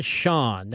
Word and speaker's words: Chaon: But right now, I want to present an interview I taped about an hour ago with a wonderful writer called Dan Shaun. That's spelled C-H-A-O-N, if you're reading his books and want to know how to pronounce his name, which I did.